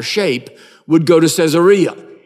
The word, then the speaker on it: Caesarea: But if I say it the way it's spelled, you'll understand. It's Caesarea.